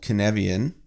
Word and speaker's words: Cynefin: I know that it probably doesn't work in a chaotic system, but I rely heavily on a framework called Cynefian. It's spelled Cynefin. It's a Welsh word,